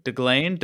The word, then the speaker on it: Deglane: Just like it's spelled. – Yep. – Deglane? – You got it.